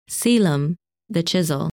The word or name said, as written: Caelum